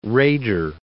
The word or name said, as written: Raeder